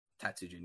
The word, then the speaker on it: Taiko no Tatsujin: And I'm going to butcher the name. I'm sorry. Taiko no Tatsujin. – Tatsujin, yes.